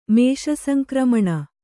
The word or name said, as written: ಮೇಷಸಂಕ್ರಮಣ